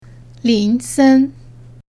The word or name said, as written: lin sen